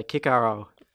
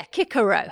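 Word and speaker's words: Cicero: And we say Kikaro. – Yeah, Kikaro, hard Cs.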